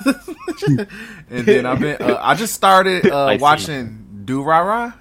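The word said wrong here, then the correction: Durarara: Is that how you say it? Do Ra Ra Ra. – Do Ra Ra Ra.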